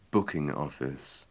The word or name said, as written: booking office